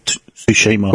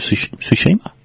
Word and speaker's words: Tsushima: Well, it's TS. TSU. Tsushima. – Was it? – Yeah, Ghosts of Tsushima.